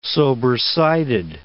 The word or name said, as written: sobersided